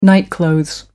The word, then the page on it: nightclothes: American English